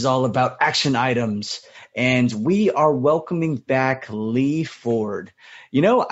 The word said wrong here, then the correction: Fjord: Your last name, is it pronounced Ford or Fjord? – Fjord. Yeah. – Oh, no. Okay. Fjord, Lee Fjord.